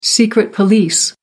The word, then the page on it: secret police: American English